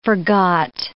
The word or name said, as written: forgot